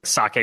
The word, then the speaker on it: psake: Yeah, just to clarify, Saki is spelled P-S-A-K-E, so it's like Saki with a P at the beginning.